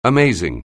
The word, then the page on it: amazing: anglais